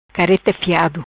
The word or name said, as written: Careta fiado